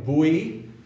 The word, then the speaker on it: Bouix: How do you pronounce that? – Is that B-O-R-I-X? – B-O-U-I-X. – Bouy.